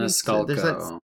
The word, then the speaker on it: Maniscalco: Maniscalco, maybe? – Maniscalco.